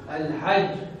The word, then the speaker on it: الحج: إذا كانت مشددة مثل الحق حج فكون أدوس عليها الحج